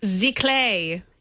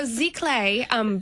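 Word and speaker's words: Giclée: So Zicle, but it's spelled G-I-C-L-E-E.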